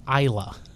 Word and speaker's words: Islay: Speaking of how not to pronounce things, this would be the island of Islay, which is Islay. – Right. – So you don't pronounce all those letters either.